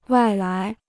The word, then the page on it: 外来: wài lái